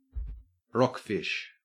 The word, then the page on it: rockfish: Australian English